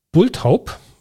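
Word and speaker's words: bulthaup: Bulltaub. Spricht man die so aus? Bulltaub? Ja, ne? – Wie sollte man es sonst aussprechen?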